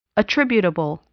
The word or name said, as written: attributable